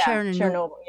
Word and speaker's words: Chernobyl: It's Chernobyl, if I'm saying that right. I sometimes get words difficult. – Yeah, Chernobyl, yeah.